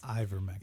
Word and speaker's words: ivermectin: I would never be anti horse dewormer because it's ivermectin.